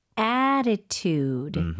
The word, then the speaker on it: attitude: Attitude. – Attitude. Attitude. 이게 앞에 TT가 있는데요. 그 부분이 조금 플랩이 나요.